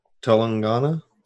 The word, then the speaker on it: Telangana: Looks like Tel- Telangana.